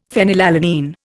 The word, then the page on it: phenylalanine: Indian